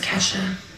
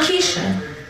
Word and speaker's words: Kesha: My name is Kesha. Kesha. Not Keisha.